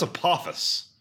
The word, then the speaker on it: Apophis: Apophis. That's Apophis.